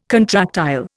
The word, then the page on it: contractile: Indian